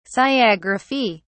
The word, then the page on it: sciagraphy: British English